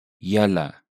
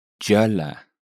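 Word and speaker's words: julle: That very first consonant you heard is actually spelled with a J. But, as you heard, it sounds like an English Y. Now, listen to the same word in Cape Afrikaans. – Jalla. – A very distinct difference.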